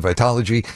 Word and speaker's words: Vitology: So heavily into Verses and Vitology.